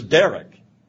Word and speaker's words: Derek: My middle name is Derek.